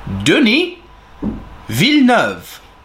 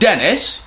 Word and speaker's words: Denis Villeneuve: Denis Villeneuve. If you want to say it with the English way, it's not Dennis. You can say Denis Villeneuve. Villeneuve is fine.